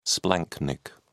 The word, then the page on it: splanchnic: British English